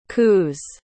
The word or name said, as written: coos